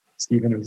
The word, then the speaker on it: Stephen: Stephen is spelled with a V.